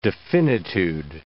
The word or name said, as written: definitude